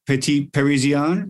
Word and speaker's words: Petit Parisien: Um, there's Petit Parisien, right? Am I pronouncing yeah. that right? – That is, yep.